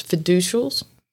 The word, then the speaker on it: fiducials: And what I read was that there's fiducials. Did I say that right? – Correct.